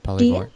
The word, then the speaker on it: Polyvore: And this is a platform that I actually had never come across before, but it's called Polyvore. Did I pronounce that right? Mm-hmm. Polyvore.